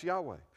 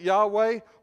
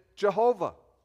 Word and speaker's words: Yahweh: If you take the word Adonai, which means Lord, and you take the vowels from Adonai and drop it in the Yahweh, you can pronounce it Yahweh, or some of the old-time Bible translators did it, they made it Jehovah.